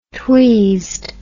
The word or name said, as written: tweased